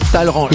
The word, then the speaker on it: Talleyrand: S'écrit Talleyrand, mais ça se prononce Talleyrand.